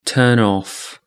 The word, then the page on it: turn off: British English